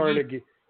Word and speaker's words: Carnegie: you mean Carnegie? Carnegie? No, it's Carnegie. People say it wrong.